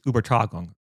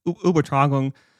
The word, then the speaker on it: Übertragung: but it's Übertragung. God, my German only is getting worse. I can still read it, but yeah, so it's Übertragung. Yeah, and it's there's an omad over the U and not over the A.